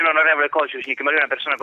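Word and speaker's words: Kožušník: E Kosunik, si pronuncia così il cognome intanto? – Dovrebbe essere Kosunik in realtà, perché in ceco l'accento va sulla prima sillaba della parola normalmente.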